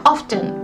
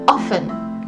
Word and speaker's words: often: と、えー、アメリカ英語の場合は where っていう感じになります where、うん、でもイギリスだと where であとはねあのしばしばっていう意味の often っていうのがありますけど、まあ、これもイギリス系の発音ででアメリカ系だと often って言って t が抜ける